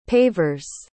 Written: pavers

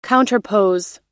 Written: counterpose

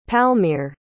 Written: palmere

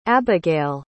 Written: abygail

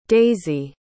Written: daysi

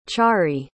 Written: chari

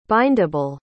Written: bindable

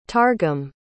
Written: targum

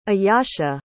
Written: ayasha

